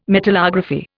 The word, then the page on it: metallography: Indian